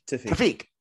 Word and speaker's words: Tawfiq: How do we pronounce this? Is it Tafiq? – Tafiq.